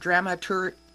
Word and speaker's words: dramaturge: So you're listed as a stage director, dramatic coach, and the last word, dramaturge. Did I say it right? – You did, yes.